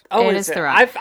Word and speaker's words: Thurrott: Is Paul Thoreau, right? Or is it Thurrott? – Thurrott. I've mispronounced that in my mind.